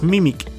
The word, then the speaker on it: meme: La palabra meme es una combinación de las palabras mimic y gene o gen en español. Por tanto, la pronunciación original de la palabra es mim.